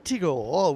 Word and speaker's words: Tigor: Tigor, we guess you should pronounce it and just ignore the spelling.